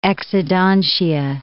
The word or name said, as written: exodontia